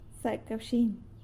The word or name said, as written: Zacawxiny!